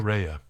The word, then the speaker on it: parea: Can you repeat the word for the crew in Greek? – Pareia. – Pareia. – P-A-R-E-A. How would you spell that? Pareia. P-A-R-E-A.